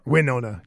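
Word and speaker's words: Wynonna: Wyona? – Winona Judd.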